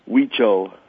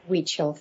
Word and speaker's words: Huichol: Huichol. – Huichol.